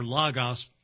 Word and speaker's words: logos: By the way, anytime you have an ology word, it means logos or logos, properly pronounced in Greek, but logos means word.